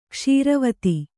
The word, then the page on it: ಕ್ಷಾರವಿಧಿ: kṣāra vidhi